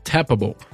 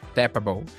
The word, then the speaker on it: Tappable: Tapable? Tapable. É, seria tipo tappable. Tappable. – É. Que é tap, T-A-P-P, a-B-L-E.